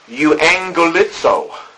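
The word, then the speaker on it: Euangelizo (εὐαγγελίζω): Euangelizo. Euangelizo. It is spelled E-U-A-N-G-E-L-I-Z-O. And the prefix, E-U-U, we get our word eulogy.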